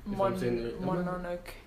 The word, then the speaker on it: Mononoke: The name is Mononoke, Mononoke.